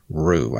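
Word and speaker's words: Rioux: by Malta Police Officer Rue. I think I pronounced that right. It's R-I-O-U-X.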